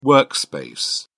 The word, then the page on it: workspace: British English